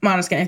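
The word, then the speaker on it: Måneskin: Yeah, I saw my Am I pronouncing that right? – Maneskin. It's Dutch for moonlight. They're an Italian rock band, but the name comes from the Dutch word for moonlight.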